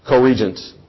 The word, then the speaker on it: co-regent: Corrigence is the term, and I'm probably mispronouncing that. King David will live for a season while Solomon is king as well. It's an overlap. Corrigence. Co-regent. Thank you.